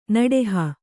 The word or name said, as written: ನಡಹು